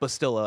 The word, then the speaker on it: Bastila: Also, shout out to the chat, Bastila, I believe was what chat told me is how to pronounce it.